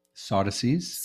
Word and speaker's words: Sadducees: Sadducees.